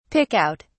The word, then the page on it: pick out: British English